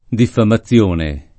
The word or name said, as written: diffamazione